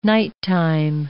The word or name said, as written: nighttime